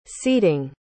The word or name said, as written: ceding